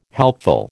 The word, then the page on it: helpful: American English